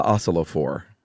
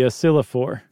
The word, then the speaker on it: oscillophore: And then a dude came along in the 1920s named Dr. Albert Abrams and his bunk science machine, the oscillophore. – Ooh, nice pronunciation. – Yes. – So Dr. Abrams not only came up with the oscillophore, I'm going to say it a different way, the oscillophore.